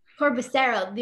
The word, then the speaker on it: Corbusiero: We're getting coffee with Pastor Michael, Corbusiero. Did I say it right? – Close. Corbusiero. – You don't say the I.